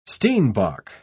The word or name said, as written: steenbok